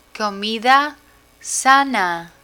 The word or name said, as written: comida sana